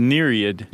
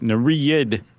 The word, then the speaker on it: Nereid: The Nereid. – Yes. – I think I'm pronouncing that correctly. – Yeah, N-E-R-E-I-D.